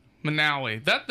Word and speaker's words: Monowi: Yeah, M O N O W I, Manawi. – Manawi. Manawi. Yeah.